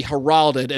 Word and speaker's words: heralded: The I said heralded. Emphasis on the wrong syllable.